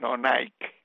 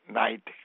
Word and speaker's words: Knight: Esto está dirigido todavía por el mismo hombre que lo fundó, que es de apellido Knight, pero no Nike, sino Knight como caballero, ¿okay?